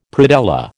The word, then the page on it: predella: American English